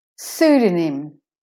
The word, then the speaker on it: pseudonym: Pseudonym. Pseudonym. So the O is de-stressed when you put the nym on the end.